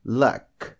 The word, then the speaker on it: luck: Vamos agora com mais um exemplo. Luck. Luck. L-U-C-K, sorte. Luck. Luck. Então, pronuncio também seco.